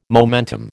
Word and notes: momentum: American English

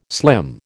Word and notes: slim: American English

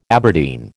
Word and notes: Aberdeen: American English

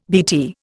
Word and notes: BT: Indian

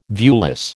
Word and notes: viewless: American English

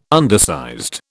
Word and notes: undersized: British English

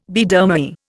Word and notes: Biedermeier: Indian

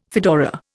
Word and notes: fedora: Indian

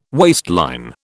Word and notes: waistline: British English

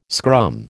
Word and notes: scrum: American English

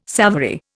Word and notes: savoury: Indian

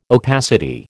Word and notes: opacity: American English

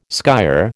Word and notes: skyer: American English

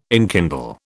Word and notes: enkindle: British English

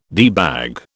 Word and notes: debag: British English

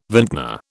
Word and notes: vintner: British English

